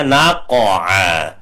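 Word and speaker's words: naqa'a (نقعا): Fa'atharuna bihi naqa'a, bukan naqa tapi naqa, naqa ya, naqa'a.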